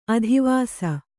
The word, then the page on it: ಆದಿವಸು: ādivasu